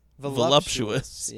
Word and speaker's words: voluptuous: That's an interesting pronunciation. Volump? – Say it the right way. – Voluptuous.